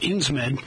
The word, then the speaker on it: Insmed: You mentioned early on your partnership with Insmed. I think I pronounced that right. If I didn't, you pronounce it correctly for me. Tell me about that partnership. – No, you pronounced that perfectly.